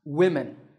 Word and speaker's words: women: Here's another example, women. This word is written with five letters and it's spoken with five sounds, but notice that both the O and the E are pronounced as short I sounds, women.